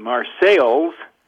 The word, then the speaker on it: Marseilles: It is located in Marseilles, Illinois, spelled the same as Marseille, France, but pronounced differently.